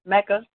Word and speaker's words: Micah: Now, what you call it, Mecca? It's Micah, mm-hmm. right? – Micah, Mecca. – Some people say Mecca, some people say Micah. – All right. Well, it's the one that's spelled M I C A H, y'all, just in case.